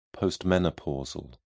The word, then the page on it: postmenopausal: British English